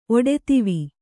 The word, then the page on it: ಒಡೆದಿವಿ: kannada